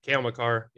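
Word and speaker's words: Makar: And what's the other guy? McCarr? – Cale McCarr. Yep.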